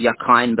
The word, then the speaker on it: Rakhine: The other thing about the particular Rakhine, it's actually pronounced Yakhine, but it's spelled R-H-A-K-I-N-E.